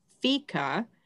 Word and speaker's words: fika: And it's a Swedish word and it's pronounced fika, but it's spelled F-I-K-A.